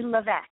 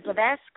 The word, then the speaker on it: Levesque: I'm fantastic, and thank you for asking because uh, it's actually Levesque. It rhymes with Beck, yes. And uh, but you know what? Every single time I'm interviewed, everybody either either says Levesque or Levesque.